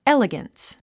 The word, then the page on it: elegance: éliɡəns; エリガンス